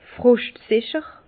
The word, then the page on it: froschtsicher: Bas-Rhin; Herrlisheim